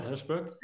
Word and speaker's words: Herschberg: Is that right, Herschberg? – You are, yep.